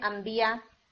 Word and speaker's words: अंबिया: मगर आप क्या बोलते हैं, अंबिया, ठीक है।